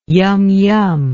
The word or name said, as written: yum yum